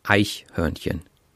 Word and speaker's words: Eichhörnchen: Okay, and is there a stress in the word? Is it like Eichhörnchen or it's on the first syllable. Eichhörnchen. – Eichhörnchen.